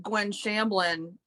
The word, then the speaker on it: Gwen Shamblin: You know, even the Gwen Shamblin and that, you know, I may be saying her name wrong, whatever, the way down. – No, you got it right.